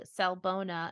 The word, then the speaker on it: Sawubona: I'm going to pr- plug Sawubona, and that is spelled S A W, like saw, U B O N A.